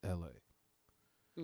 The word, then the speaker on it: Elhae: Like, because it's spelled E-L-H-A-E. So it's L-H-A is what it looks like. But if you say it, it's just L.A.